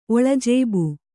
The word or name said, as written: ಒಳಜೇಬು